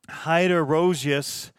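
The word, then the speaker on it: Heideroosjes: And here's a phonetic pronunciation. Heiderouges. Heiderouges. So there we go.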